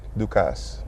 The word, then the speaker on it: Ducasse: Ducasse. Ducasse. That's how you say it, right? – Ducasse. – Ducasse. – Yep.